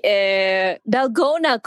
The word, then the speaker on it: Dalgona: Oh, you mean Delgado? Wait, no, I'm saying it wrong. – Delgado? – No, one with the foamy Dalgona coffee.